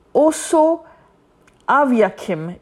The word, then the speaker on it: Osoaviakhim: But what is important here, I'm also going to talk about the Operation Osa Viakim. I can't pronounce it very clearly, my friend. It's Operation Oso Aviakim.